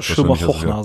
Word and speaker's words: Shylmagognar: Ja, ich kann es mal buchstabieren und du kannst mir dann sagen, ob ich so, also Deutsch wird man es wahrscheinlich Schilmar Gockna aussprechen, also S-H-Y-L-M-A-G-O-G-N-A-R. – Ja, aber die Holländer, die haben ja immer diesen Ch. – Bitte? – Ja, die Holländer machen immer diesen Ch. – Ja, genau, Schilmar Hochna, sag ich ja.